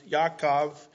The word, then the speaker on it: Yaakov: So his name was called Jacob, I think is pronounced Yaakov in Hebrew, second son.